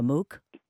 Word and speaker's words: MOOC: Is that the way I'm supposed to refer to it, Maria, a MOOC? – Yes, okay.